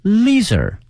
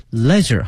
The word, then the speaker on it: leisure: 但是今天我们学的另外一个词也表示休闲哈，叫 leisure 哈，也叫做 leisure 哈，都可以哈，两个发音。美式呢是 leisure 哈，英式呢是 leisure，它的拼写呢是 leisure 哈。